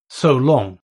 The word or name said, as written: so long